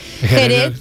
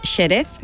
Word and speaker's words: Jerez: Jerez en principio era con una X, pero ahora se escribe con J y se pronuncia con J. Nadie dice Xerez.